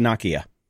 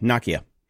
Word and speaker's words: Nokia: And, and by the way, it's pronounced Nokia. Is it really? Yep, Nokia.